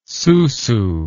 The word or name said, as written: susu